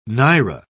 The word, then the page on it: naira: British English